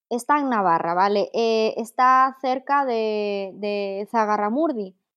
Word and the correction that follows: Zugarramurdi: Zugarramurdi, donde lo de las brujas de Zugarramurdi, que hay un montón de leyendas alrededor de esa zona.